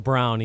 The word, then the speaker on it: Braun: They're saying his name is Christian Brown, but his name is spelled Christian Braun. – Yeah, I confirmed that this morning. It is pronounced Brown, even though it's spelled Braun.